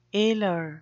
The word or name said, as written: alar